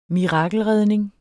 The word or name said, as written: mirakelredning